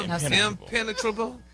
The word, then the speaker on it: impenetrable: Impenetrable. Impenetrable. – No, your emphasis is on the wrong syllable.